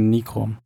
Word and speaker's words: Nikon: Es ist immer Nikon. Ja, Nikon, ja. Nikon Pros. Du hast dich schon von den YouTubern, die es falsch aussprechen, äh, Shame on me.